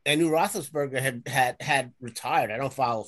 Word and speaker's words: Roethlisberger: Roethlisberger.